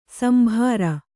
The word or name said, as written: ಶಾಂಬರ